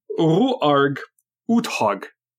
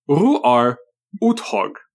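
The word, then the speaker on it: Roar Uthaug: Coldplay is directed by, I didn't look up how to say this one, but it's like Roar Dog. – I know how. – Okay. – I know how. My friend El Goro from the Talk With A Rhythm podcast told me it's pronounced Ruarg Uthog.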